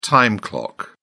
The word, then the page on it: time clock: British English